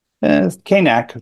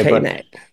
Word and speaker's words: Kanack: Kanack. It's a long A. Kanack.